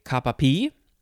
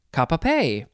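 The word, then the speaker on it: cap-a-pie: Okay, next we have, let's see, you could say kapapi, or you could also say kapape. It is spelled C-A-P, well, I'll just say it's cap, second word is just the letter A, and the third word is pie, P-I-E, but they say P or pay.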